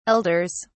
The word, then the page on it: elders: British English